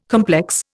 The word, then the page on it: complex: Indian